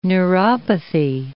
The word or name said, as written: neuropathy